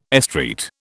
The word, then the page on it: estreat: British English